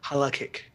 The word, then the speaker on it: halakhic: Oh, halakhic.